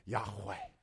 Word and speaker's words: Yahweh: You got to say it like with your throat. Yahweh.